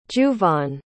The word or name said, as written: juvaughn